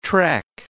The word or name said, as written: trek